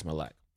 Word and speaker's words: Malik: And then the number one question is, how do you pronounce Malik? And I'm just like, it's Malik.